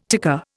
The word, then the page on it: ticker: Indian